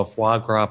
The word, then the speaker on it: foie gras: Can I piggyback on Jim's little plan to ask you and Jim to have a foie gras pronunciation competition? – Okay, Isn't that about to come?